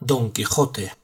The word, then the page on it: Don Quijote: sur de España